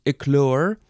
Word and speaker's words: éclore: It is from the French eclosion, from éclair, no, how do you say that? Éclore, which means to hatch.